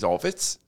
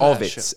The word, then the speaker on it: Ovits: My name is Ovitz. – Oh, Ovitz. I love Ovitz. O-V-I-T-S.